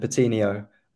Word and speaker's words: Patino: I want to ask you, about, say if I'm saying this wrong, Charlie Patino, is that right? Is he the next big thing at Arsenal and might we see him? – Yeah, we call him Patino.